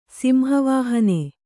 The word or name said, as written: ಸಿಂಹವಾಹಿನಿ